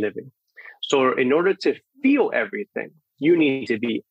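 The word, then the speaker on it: FEAL: By the way, for those listening, it's spelled FE:AL, and it's pronounced feel.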